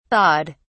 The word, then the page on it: thawed: British English